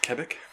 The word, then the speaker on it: Quebec: There is a survival adventure game called Kona, which takes you through northern Quebec where you'll uncover a murder mystery with supernatural undertones. – It's pronounced Quebec. – Oh, Quebec. not Quebec. And